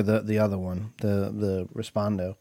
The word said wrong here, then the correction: Reposado: Reposado. – Reposado. I knew I said it wrong.